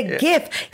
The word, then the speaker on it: GIF: It is a GIF. It stands for graphics. – Yes. Yes. Yes. – It is the G in graphics. – Yes. – If you pronounce graphics, draphics, then you're allowed to say GIF.